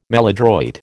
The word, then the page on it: maladroit: American English